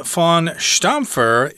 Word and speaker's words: von Stampfer: No, I think you're correct with that one. Von Stampfer. – Yeah, I think I said fawn or something, but it doesn't really matter.